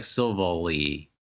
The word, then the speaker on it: Silvally: There's Silvally. No E to make it like Valley. – Silvally? Yeah, so it's more like Silvally.